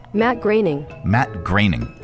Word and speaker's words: Groening: how do you pronounce his name? I always get it wrong. – Matt Groening.